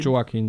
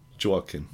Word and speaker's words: Joaquin: Spricht man ihn Joaquin? – Nee, ich würde Joaquin sagen. Joaquin.